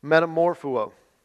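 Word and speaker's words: metamorphoō: The word that Matthew and Mark both use to describe what they saw is pronounced metamorpho.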